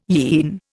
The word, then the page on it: yean: Indian